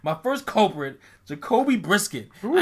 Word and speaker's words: Brissett: Brissett. Say his name right.